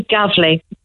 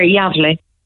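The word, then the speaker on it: Gävle: From Mallow to Gavley. Or as they pronounce it here, Yavley.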